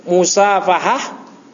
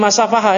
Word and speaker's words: musafahah: Nah ini musafahah. Ini jangan bacanya masafahah ya.